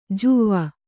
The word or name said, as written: ಜುವ್ವ